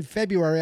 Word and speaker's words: February: Yes, I pronounced the first R in February. I said February.